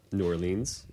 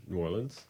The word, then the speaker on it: New Orleans: Well, I would say that I just realized I did mispronounce New Orleans. – Oh. – So if, well, if let the record be corrected. – If anybody's listening from there, I'm sorry. It's not New Orleans. It's New Orleans.